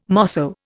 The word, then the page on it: mosso: Indian